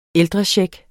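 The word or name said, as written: ældrechecks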